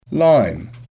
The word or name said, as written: lime